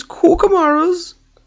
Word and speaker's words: Cucamaras: And just for the avoidance of any doubt, the correct pronunciation, or at least according to me, is Corkamaras. You should only say it in that fashion.